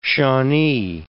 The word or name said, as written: Shawnee